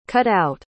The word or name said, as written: cut-out